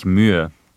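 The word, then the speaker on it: Mühe: I mean, the sound, which is in his last name, doesn't actually exist in the English language, so it's actually Ulrich Muha.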